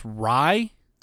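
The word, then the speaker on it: Raï: Um, and he is known mostly for kind of meshing uh, the style of r- I think it's pronounced Rye. Yeah, R A I.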